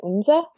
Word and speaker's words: Oomza: It has been a year since Binti and Okwu enrolled at Oomza University, and I will pause to say to ask how you pronounce that. – Uh, I, I think I pronounce it Oomza. Okay, that's what I said too.